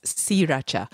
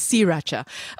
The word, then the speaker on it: sriracha: Andrew also says, by the way, I think sriracha is pronounced as sriracha. And I just said it the way that we said it at the start during our Thailand-Vietnam kerfuffle. But also because... Yeah, I think you're right. I have seen... So the founder of the company has confirmed this, that it is sriracha. I think that... Even now I'm saying it wrong. I think it's sriracha.